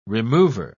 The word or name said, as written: remover